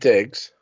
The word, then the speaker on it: Diggs: There's an actor, I can't pronounce his name, and David Dukes. I think I just said that, right? – David Diggs.